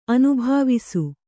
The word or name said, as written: ಅನುಭಾವಿಸು